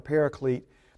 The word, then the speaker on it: paraclete: Now, so another helper, the original word in the Greek is the word paraclete. And the transliteration is spelled P-A-R-A-C-L-E-T-E, paraclete.